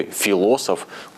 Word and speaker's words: Философ: Не спешите поправлять меня, мол, что за безграмотность была сейчас в эфире в слове «философ», ударение падает на второй слог. А в данном случае именно «философ», и это имя батюшки, протоиерея видного церковного деятеля царской России, расстрелянного на берегу Финского залива в 1918 году.